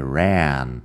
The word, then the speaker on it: ran: Lo único ahí es la pronunciación de ran, es con e, que es un sonido que no existe realmente en español y bueno,